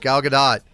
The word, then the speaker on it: Gadot: And don't at me that it's Gal Gadot. It's Gal Gadot. Israeli, not French. That's how it's pronounced.